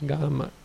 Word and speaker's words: gama: It's something to do with the village people, people who live in a gama, with a long A, G-A, G-long A-M-A is gama.